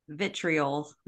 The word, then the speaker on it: vitriol: virtual on the platform. Is that how you say it? Virtual? – Vitriol. – Vitriol. Thank you.